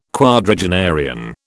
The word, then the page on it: quadragenarian: British English